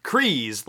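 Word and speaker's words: Kryze: And additionally for me, luckily, the whole pronunciation thing has been settled, so Kreese, like Freeze, is how you pronounce Bo-Katan's last name.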